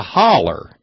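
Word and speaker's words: hollow: It's a holler. H-O-L-L-E-R. Actually, it's H-O-L-L-O-W, pronounced holler.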